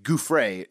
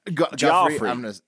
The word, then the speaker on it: Giuffre: Number three, Ghislaine Maxwell and her attorneys have decided to stop protecting 8 unknown John Does as part of the Virginia Guffrey civil case. How do you say that? – Guffrey. It's like like the mascot for Toys R Us. – Guffrey. – Joffrey. – Yeah. Okay. – It's That's civil. – Giuffre. – G-I-U-F-F-R-E.